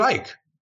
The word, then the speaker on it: Icke: In, I think it was the spring of 94, David Icke, or Ikey, depending on your pronunciation, showed up and spoke.